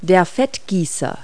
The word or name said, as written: baster